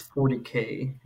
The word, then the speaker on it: 40K: I believe it's 40K. Is how you pronounce that? Yeah, well, it says 40,000 right there, but you're right. It is 40K.